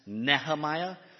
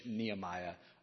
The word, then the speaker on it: Nehemiah: And I'll just say to start, Nehemiah is probably best pronounced Nehemiah. But I do not speak Hebrew, so I'll just be saying the more English Nehemiah, because that's what I grew up with.